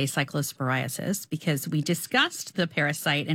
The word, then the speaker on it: cyclosporiasis: If you are worried, there is a CDC cyclosporiasis. I didn't pronounce that right. – Outbreak cyclosporiasis. Yes.